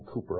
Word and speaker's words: Cowper: His name is William Cowper, although that's not how it's spelled. It's spelled C-O-W-P-E-R.